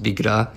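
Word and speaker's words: Bigras: My last name is uh, pronounced Bigra. It's, it's kind of French. It's B-I-G-R-A-S was man nochmal aus der buchstabierten ich, Sprachweise.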